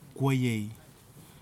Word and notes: kuayei: kwamera

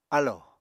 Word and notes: álò: Apatani

